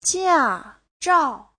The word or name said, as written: jia4zhao4